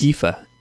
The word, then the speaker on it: Aoife: No, her name is pronounced Aoife. – Aoife Nessa Francis. – Aoife. A-O-I-F-E.